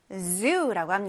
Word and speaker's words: zoo: 동물원을 영어로 zoo라고 합니다. 여기에서 z 발음 유의해서 우리 혀를 윗니와 아랫니 사이에다 놓고 z 이렇게 목에 손가락을 대면 z 울리게 발음할 거예요. zoo. zoo. 맞아요.